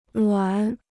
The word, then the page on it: 卵: luǎn